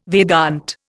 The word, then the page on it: Vedanta: Indian